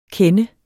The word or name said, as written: kende